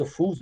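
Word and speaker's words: Ophüls: Estou gravando com vocês agora, por exemplo, para ver na tarde o, o Max Office, né? O Max Ofus, não, né, é, o Fis é a pronúncia francesa, né?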